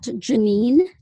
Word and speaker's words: Jenine: You can actually send me an email at Janine Stanley, and that's JENINE.Stanley@IRA, which is spelled AIRA.IO.